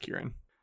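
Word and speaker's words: Ciarán: He's got one of those weird looking Irish names that's pronounced Kieran.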